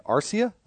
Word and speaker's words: Arcia: And Arcia, that's how I'm supposed to pronounce it? Mm-hmm.